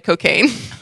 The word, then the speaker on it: Cokagne: The Diet Cocaine. But it's spelled C-O-K-A-G-N-E. Like champagne.